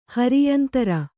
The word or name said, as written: ಹೋರಾಯಂತ್ರ